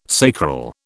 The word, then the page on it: sacral: British English